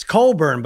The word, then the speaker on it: Cockburn: Everyone is very excited to say Cockburn when it's Coburn, but you say Coffee for Kofi.